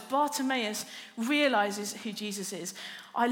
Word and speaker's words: Bartimaeus: Because Bartimaeus, I think it's four syllables, Bartimaeus, and it's really going to slow us down this morning.